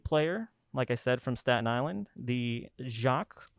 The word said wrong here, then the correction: Jacques: Award winner, Jacques, right? Did I say that correct? – Jakes. – It's spelled like Jacques, though. – Yeah. – It's Jakes, though.